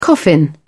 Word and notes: coffin: British English

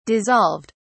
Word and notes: dissolved: British English

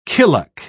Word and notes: killock: British English

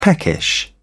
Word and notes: peckish: British English

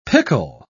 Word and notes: picul: British English

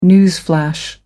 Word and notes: newsflash: American English

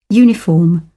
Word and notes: uniform: British English

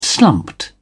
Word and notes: slumped: British English

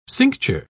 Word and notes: cincture: British English

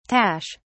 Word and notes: tache: British English

